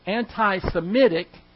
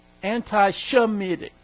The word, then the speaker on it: anti-Semitic: And so when we say that someone is anti-Semitic, we're actually saying anti-Shemitic. But it's not pronounced that way.